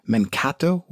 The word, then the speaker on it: Mankato: Becca from Encanto, Minnesota. Am I saying that correctly? Mankato? What's she talking about, you ask? The TV show is called Kolchak, K-O-L-C-H-A-K.